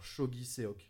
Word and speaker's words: Chogiseok: Voilà, alors c'est Chog alors, Shogi Seok. Bon, C-H-O-G-I-S-E-O-K.